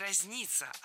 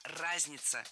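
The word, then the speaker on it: разнится: По цивилизациям это разница. – Нет, не разница, а разница. Представляете, в этом слове ударение должно падать на первый слог.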